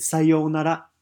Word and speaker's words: sayonara: For example, I once helped a businessman who, after saying goodbye to somebody, said sayonara. Sayonara. Now, make sure you have the long O sound.